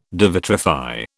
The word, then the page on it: devitrify: British English